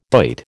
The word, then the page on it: doit: American English